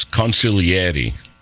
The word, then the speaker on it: consigliere: First of all, it's consigliere, not consigliere. The correct pronunciation.